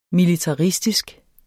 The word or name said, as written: militaristisk